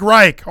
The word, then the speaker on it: Reich: Frank Weich? I can't pronounce it. Frank Reich.